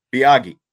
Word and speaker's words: Miyagi: Well, like I said, I found one and it said Miyagi, and that's okay. What I was going with. – So it's like Mr. Miyagi.